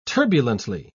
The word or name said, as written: turbulently